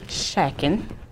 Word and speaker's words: Schatkin: Today's guest is Andrew Schatkin.